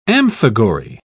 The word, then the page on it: amphigory: British English